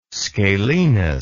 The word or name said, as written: scalenus